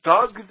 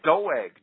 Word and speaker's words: Doeg: So Doeg the Edomite, Doeg, D-O-E-G, it's spelled in the King James, the Edomite, volunteered to kill the priests of Yahweh for Saul.